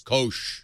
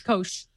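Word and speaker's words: Koush: How do you say it? Kosh. – Kosh. – There's a U, but the U is silent. – Yep. – Kosh.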